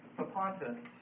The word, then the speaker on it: Propontis: Propontis. And that's spelled P-R-O-P-O-N-T-I-S.